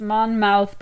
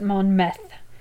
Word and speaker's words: Monmouth: My town that I'm from isn't pronounced Monmouth, but Monmeth.